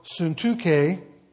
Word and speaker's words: Syntyche: I implore Euodia and I implore Syntyche, I know it's spelled funny, but that's how they say it, to be of the same mind in the Lord.